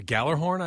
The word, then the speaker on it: Gjallarhorn: How do you say the name of that horn? – The Gallerhorn, I believe. – There you go, that one. I didn't want to say it wrong, and there's a J in it, by the way. – I think it's some sort of Scandinavian spelling. – G-J-A-L-L-A-R-H-O-R-N.